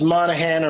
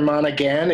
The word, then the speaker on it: Monaghan: I've never known for sure if it's Monahan or Monaghan, if you pronounce the G. – Uh-huh. – But I think you're probably right about not pronouncing the G, and you're definitely right about everything else.